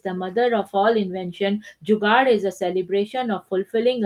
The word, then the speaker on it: Jugaad: At some point in their life, every Indian has experienced Jugaad.